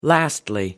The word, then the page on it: lastly: American English